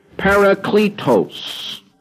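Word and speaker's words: parakletos (παράκλητος): The word for comforter in Greek is pronounced ho parakletos, which means the comforter. Its pronoun is, because it's in the masculine tense, autos, A-U-T-O-S, he.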